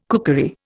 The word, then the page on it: cookery: Indian